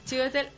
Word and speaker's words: Chiwetel: Con Julia Roberts, Nicole Kidman y Sewater Ejiofor. No sé pronunciar el nombre de este señor. – Chiwetel Ejiofor.